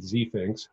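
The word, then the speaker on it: Zfinx: And I pronounce it Z-Finks. Other people pronounce it otherwise.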